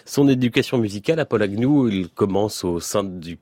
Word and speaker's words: Agnew: En tout cas, son nom Agnew se prononce Agnew et pas Agnew, A-G-N-E-W.